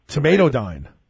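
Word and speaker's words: tomatidine: Tamotidine? – It's actually tomato. It's pronounced tomatodyne.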